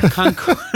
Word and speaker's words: Concord: Are you pronouncing that name right? Oh. Is it Concord? Concord. – You caught me. You caught me. That's right. You know, old habits die hard.